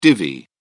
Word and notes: divi: British English

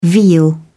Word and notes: veal: British English

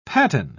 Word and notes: paten: British English

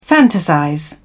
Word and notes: fantasize: British English

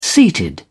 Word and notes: seated: British English

